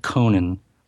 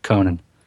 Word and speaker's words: Conan: And they're officially pronouncing it Conan. Multiple characters in the different trailers, Conan.